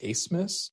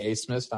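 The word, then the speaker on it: Abmas: Max, his last name is pronounced Asmus. Apparently the B makes an S sound.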